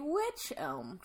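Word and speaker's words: wych elm: On the estate, there was a witch elm tree. – So not a witch, just a witch. – Yes, and it's spelled W-Y-C-H. Okay. Witch elm is pronounced like witch, though.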